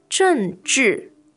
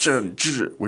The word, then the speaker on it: 政治: 政治. – Now, that sounds They're both fourth, yes. – 政治, which is quite appropriate. – Harsh, yeah, quite. – 政治, meaning politics.